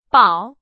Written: 保

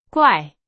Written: 怪

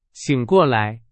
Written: 醒

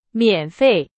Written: 免费

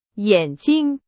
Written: 眼镜

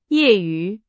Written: 业余

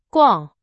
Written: 逛

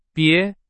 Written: 别

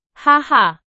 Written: 哈哈

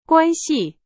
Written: 关系

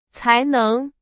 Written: 才能

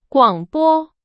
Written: 广播

